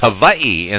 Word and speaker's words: Hawaii: All right, we've got Hawaii from, actually that's pronounced Hawaii, incidentally, to be politically correct. The W's in the Hawaiian language are pronounced as a V instead of a W.